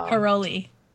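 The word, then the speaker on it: Károlyi: I'm sure I'm going to butcher this, but Bella Carolia. Caroli. There you go.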